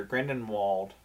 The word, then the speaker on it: Grindelwald: I think what I like about it is I think Johnny Depp's character, Grindelwald, as they pronounce it. Well, it depends on who you say. If Dumbledore says it, there's the V. If everybody else says it, it's a W.